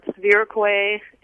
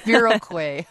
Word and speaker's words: Viroqua: Viroquay. People sometimes just sit and stare at it and don't say anything. – Viroquay.